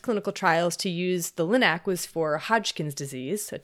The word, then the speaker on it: LINAC: But then the physicists developed something called the linear accelerator, or LINAC. I think that's how you say it. – Sounds right.